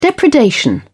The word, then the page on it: depredation: British English